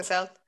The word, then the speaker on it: Gothel: So Mother Gothel, I don't think I'm pronouncing that right, Gothel from Tangled. Gothel. – Yes.